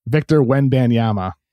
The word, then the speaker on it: Wembanyama: How do you say it? – Victor Wembanyama.